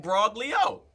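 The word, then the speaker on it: imbroglio: I said IMBROGLI and Broglio. – The G is silent.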